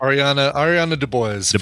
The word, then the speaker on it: Ariana DeBose: Ariana DeBose.